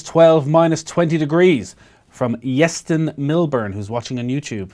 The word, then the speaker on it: Llestyn: Let us know, Yeston. It's spelled LLESTYN. – Yeston, yeah.